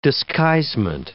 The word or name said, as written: disguisement